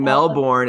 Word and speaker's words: Melbourne: It's not that they don't pronounce their R's always. It's just like, and when it's, honestly, it's before an N, right? So Cairns, Melbourne, it's the R goes silent.